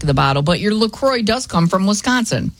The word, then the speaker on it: La Croix: So officially, it's pronounced La Croix, so it rhymes with enjoy, not La Croix or La Croix, like the French might say it.